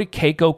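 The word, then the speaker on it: KECO: Once again, guys, go to Keiko Capital.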